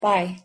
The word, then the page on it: bye: English